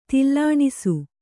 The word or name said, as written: ತಲ್ಲಣಿಸು